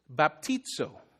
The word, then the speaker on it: baptizo: And that is Greek for baptize. It's pronounced baptizo.